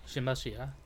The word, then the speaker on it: Shinbashira: Shinbashira? Am I saying that right? – Yeah. the heart pillar. – Yeah. Oh. Yeah, okay. Shinbashira.